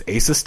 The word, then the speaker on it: Asus: Part of this investing group is the co-founder of, I think it's Asus Tech. Is that how you pronounce it? – Yes, that's right.